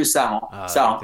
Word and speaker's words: Saran: 217.5 kilometers, big long day from Chauvigny to Saran. Did I pronounce those right? – Chauvigny to Saran. – There we go.